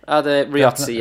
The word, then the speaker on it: Adam Riozzi: Yeah, Adam Riozzi, yeah.